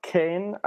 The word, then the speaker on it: Kean: It was, you might be able to pronounce this guy's first name for me, Moise Kean. Is that all right? – It should be more like Kane, I think. Something in between Kean and Ken.